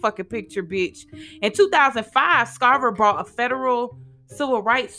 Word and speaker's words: Scarver: Like the S is silent. The S ain't motherfucking silent, bitch. It's Scarver. Scarver stated that he spent 16 years in solitary confinement and the result of only Jeffrey Dahmer's killing.